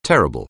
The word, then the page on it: terrible: anglais